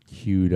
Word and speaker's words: queued: How do you pronounce that word? – It's pronounced Q. – Queued up. I'm sorry. Queued up. – And by the way, that's the word Q-U-E-U-E.